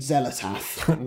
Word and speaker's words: Xel'lotath: Either there, there's Chattur'gha, Ulyaoth, and Xel'lotath.